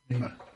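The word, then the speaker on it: Neneh: Bit of Neiman, Neiman Cherry, how do you say her name? Um, Nina. Nina. Um, I've just been told by the boss it's Nina.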